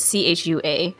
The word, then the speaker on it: Chua: It's by Kat Zhang, and it's illustrated by Charlene Chua, I think, is how you pronounce it. It's Chua.